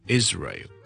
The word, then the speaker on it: Israel: 第一件事咧，以色列呢个国家咧，我哋咧个发音系 Israel, Israel, Israel, Israel。有好多件事要留意嘅，就系、是、第一件事咧，嗰、那个 is 咧，虽然我串发系 s 咧，我哋嘅读音系读 z 嘅，所以系 is Israel, Israel, Israel, OK? 第二件事要留意嘅呢就係、是、呢個 ray 完之後呢 Israel, 你當係 ray, ray 咁樣 ,I want ray, Israel, 之後我條脷要上返去個 L 音 Israel, Israel, Israel, Israel, OK?